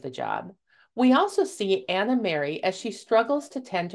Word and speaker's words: Anna Mary: The Horseback Librarians tells the tale of the fictitious Anna Mary, rhymes with library, as she travels her rounds delivering books to kids in their homes and schools.